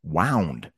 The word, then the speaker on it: wound: Past tense is wound. Sorry, wound. Wound. Wound. That's how you pronounce that.